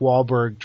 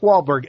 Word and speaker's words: Wahlberg: Mark Wahlberg, every time I spell it, I spell Wahlberg wrong. And well, there's that H in there for no reason. – It's a sneaky little H. Some wizard stuck in there when they were handing out names.